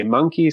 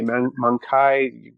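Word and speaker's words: mancae: And then they're called, now you'll hear many different pronunciations of this word, but it's M-A-N-C-A-E. Some people say monkey, some people say mankai, all sorts of things. But that is the term for very small juvenile isopods.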